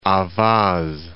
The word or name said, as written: Ahvaz